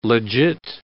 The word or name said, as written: legit